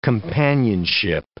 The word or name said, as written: companionship